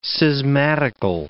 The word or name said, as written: schismatical